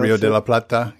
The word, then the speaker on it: Rio de la Plata: Rio de la Plata, yes.